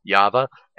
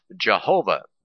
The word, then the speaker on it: Yahweh: Christians erroneously pronounce Yahweh as Jehovah. Well, now they're changing to Yahweh, but still there is no letter like that in the Hebrew alphabet, and then they have another excuse about that, about it.